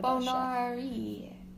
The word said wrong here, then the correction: Boyardee: Are you trying to say Chef Boyardee? – Yes.